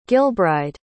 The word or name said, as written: gilbride